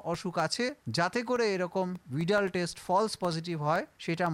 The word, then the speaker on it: Widal: এনারা একটা পরীক্ষা করেন, যে পরীক্ষাটাকে বিডাল পরীক্ষা বলে, অনেকে উচ্চারণ ভুল করে এটাকে ওয়াইডাল পরীক্ষাও বলে, WIDAL, কিন্তু উচ্চারণটা ভিডাল টেস্ট।